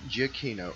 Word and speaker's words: Giacchino: And the name is Michael Giacchino. – Giacchino. I wasn't sure if that was a hard G or a soft G at the beginning. – Giacchino.